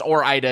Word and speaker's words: Ore-Ida: I think Ore-Ida is close. I mean, I think there's a case for that because it is like Oregon. And so you start to do, if you start to pronounce that next syllable, there's a case for Ore-Ida. But I believe it's Ore-Ida is the correct pronunciation.